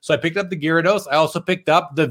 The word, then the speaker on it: Gyarados: From my childhood, I said Gyarados all the time. So Gyarados. Yeah, that's what my kids said too. I couldn't pronounce it either. And my son's like, it's Gyarados, Dad.